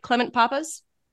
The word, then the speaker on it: Clement Pappas: Clement Pappas? – Yeah, Pappas.